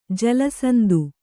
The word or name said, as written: ಜಲಸಂಧಿ